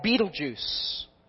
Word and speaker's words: Betelgeuse: And there's another star that is, I'm not even going to try to spell it for you, but it's basically pronounced like Betelgeuse.